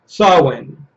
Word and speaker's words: Samhain: Samhain, pronounced Samhain.